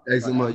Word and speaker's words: eczema: Eczema? – Eczema, yeah.